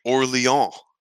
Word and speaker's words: Orléans: As you both know, in the past week, I have played with you the game Orléans.